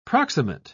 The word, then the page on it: proximate: British English